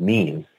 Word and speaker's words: meme: and maybe the notion of memes, and do them all at once. Because now, you mentioned that word meme, that's spelled M-E-M-E. It's sort of like gene.